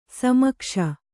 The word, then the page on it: ಸಮೀಕ್ಷೆ: samīkṣe